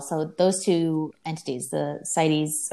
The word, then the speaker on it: CITES: CITES.